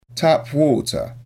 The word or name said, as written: tap water